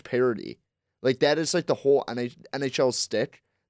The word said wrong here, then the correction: shtick: That like shtick. Did I say that right? – Shtick. There you go.